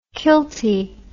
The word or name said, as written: kilty